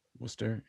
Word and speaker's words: Worcester: Yeah, but it looks like Warcrester kind of. Worcester. Yeah. Well, it's like the street in Bowling Green, Ohio. It's spelt W-O-O-S-T-E-R, Wooster, right? So you can kind of, you can say it the correct way, but that would be spelled very differently in New England.